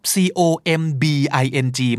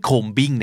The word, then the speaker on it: combing: c o m b i n g ไม่ combing นะครับ combing เหมือนไม่มีตัว b เลยครับ